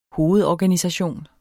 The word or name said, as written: hovedorganisation